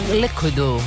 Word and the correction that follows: Liquido: No, cadi sulla I l'accento liqui. – Ah, Liquido.